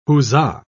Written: huzzah

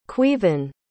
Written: Caoimhghin

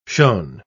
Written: shone

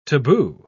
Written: taboo

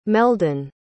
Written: meldon

